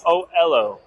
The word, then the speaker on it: Oelo: And today I'm here, and we are at Oelo. Is that, how do you pronounce this? – Oelo. – Oelo. – Oelo, like okay. Hello.